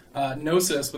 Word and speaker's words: Gnosis: Gnosis, with a silent G, is an ancient Greek word meaning knowledge, with connotations of secret knowledge or spiritual knowledge, specifically also knowledge of God.